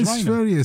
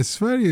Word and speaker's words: Sverige: No, Sverige. Sverige is the right, correct pronunciation. Well done, you. Yeah, it's Sverige.